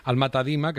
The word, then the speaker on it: Alma Tadema: Por cierto, creo que lo hemos pasado así de bordeándolo, pero Alma Tadema, que es como tú lo has pronunciado, es un pintor británico, ¿no?